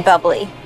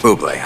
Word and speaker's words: Bublé: You mean bubbly. – No, I mean Bublé. – You're Michael Bublé. That's a bubbly. – She's right, Michael Bublé. It's pronounced Bublé.